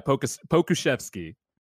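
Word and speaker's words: Pokusevski: And I'm also happy that I pronounced his name correctly on this podcast. – Pokushevsky? – Yeah, Pokushevsky.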